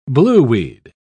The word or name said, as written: blueweed